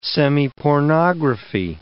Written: semipornography

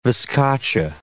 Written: viscacha

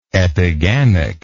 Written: epigamic

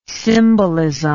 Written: symbolism

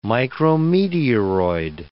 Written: micrometeoroid

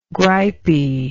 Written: gripy

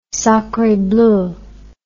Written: sacre bleu

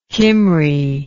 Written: Kymry

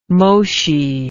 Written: Moshi

